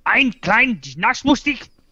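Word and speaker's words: Eine kleine Nachtmusik: I'm gonna pronounce this wrong. Ein Klein Notch Music. There you go. You can queue up at zero if you like. – Yeah, there it is. – How would you pronounce this? – Is it the Sarah X does Mozart's Ein Klein Notch Musik? – Well done. I like it.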